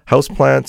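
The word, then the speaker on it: Hausplants: Houseplants, spelled like house, like H-A-U-S, plants.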